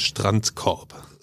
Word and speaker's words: Strandkorb: Strandkorb.